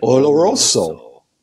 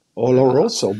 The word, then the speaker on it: Oloroso: We got to teach you how to say it. Say it. – Oloroso. Oloroso. But when you say it, you get deep into the Canadian you get club. Deep into the rolling of the R's.